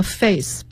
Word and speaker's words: phase: 好，这里边有一个 face， P-H-A-S-E，它和脸 face 发音是一样的。